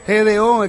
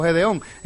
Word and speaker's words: Gedeón: Gideon. ¿Cómo se dice eso, Gideon? No, Gideon. El ejército de 300, ¿se recuerda? Gedeón. Es que estoy de inglés, español todo el tiempo. Gedeón.